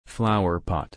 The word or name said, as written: flowerpot